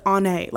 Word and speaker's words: Ane: One is called Ane and that's how she told me to pronounce it. The author literally said Ane, like she pronounced it like that.